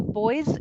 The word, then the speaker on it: boys: So the boys is almost more of a z sound. Boys and voice. It's more of an S sound at the end.